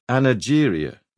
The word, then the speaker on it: anageria: The first respected doctor to give it one back in the 1890s called it anageria with a soft G.